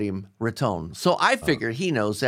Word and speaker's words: Riton: I didn't say it right either, but I was saying writing or something else, and you were like, no, it's Ritone.